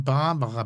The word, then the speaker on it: Barbara: Ein tolles Buch von Barbara Becker. – Das kann sein. – Also mit irgendeiner Ex-Frau von... – Betonung auf Bar.